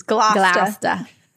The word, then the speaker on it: Gloucester: So his uncle, Richard, Duke of Gloucester, how do you say it? I feel like Gloucester. But in Massachusetts, it's Gloucester.